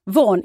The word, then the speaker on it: Vaughan: Jag ska kanske säga Vaughan. – Vaughan, inga G ska höras.